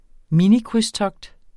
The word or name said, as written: minikrydstogt